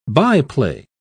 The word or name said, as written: byplay